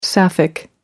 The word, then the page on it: sapphic: American English